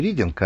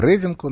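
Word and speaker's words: Reading: Не Рединг, а Рединг он. Mm-hmm. Он только пишется как Рединг, а читается как Рединг. Потому что это не от глагола «рид».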